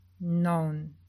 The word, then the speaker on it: nan: Para designar mato ou floresta, se escreve nan, mas se lê non.